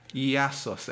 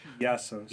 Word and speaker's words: Iasos: how do you pronounce Iasos' name again? Yassos, I think. Yassos.